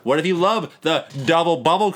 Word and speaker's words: Double Bubble: But the thing is, there's a lot of It's double bubble. Okay, you've been saying it wrong the entire time. You haven't given it an ounce of respect. – Sorry. Double bubble.